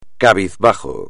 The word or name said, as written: cabizbajo